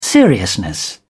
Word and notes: seriousness: British English

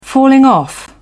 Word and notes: falling off: British English

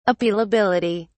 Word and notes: appealability: British English

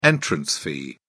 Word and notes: entrance fee: British English

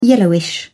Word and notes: yellowish: British English

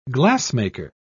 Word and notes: glassmaker: British English

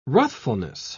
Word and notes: ruthfulness: British English

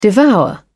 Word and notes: devour: British English

